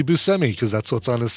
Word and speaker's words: Buscemi: But the correct, Sicilian pronunciation is Buscemi. So when people say Buscemi, that's very close to how I think what the real pronunciation is.